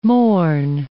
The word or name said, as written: mourn